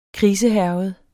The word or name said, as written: krisehærget